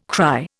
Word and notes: cry: Indian

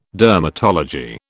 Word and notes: dermatology: British English

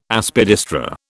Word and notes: Aspidistra: British English

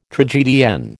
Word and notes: tragedienne: American English

